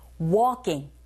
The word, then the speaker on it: walking: 주의하실 건 L이 묵음이라는 거. Walking, walking. 크 발음이요. 살짝 된소리가 나서 walking보다는 walking, walking 하시면 됩니다.